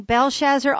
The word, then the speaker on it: Belshazzar: From the time that Nebuchadnezzar died until Belshazzar's reign began, and by the way, let me say, I have been saying Belshazzar all along. I looked it up. It's the correct pronunciation is Belshazzar with a D sound and with the accent on the second syllable, not the first.